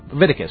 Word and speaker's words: Leviticus: Okay, Leviticus. That's what I'm going to say. I want to say another syllable, but I just can't get my mouth to do that.